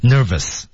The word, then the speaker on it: nervous: nervous 这个单词大家注意还是咬一下唇，nervous 啊，nervous 啊，上齿咬下唇内侧。 – Okay. Nervous, 不要读成 nervous, 这是不对的 nervous.